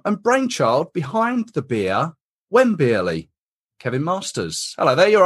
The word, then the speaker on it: Wembeerly: Wembeerly, yeah. Wembley with beer in the middle.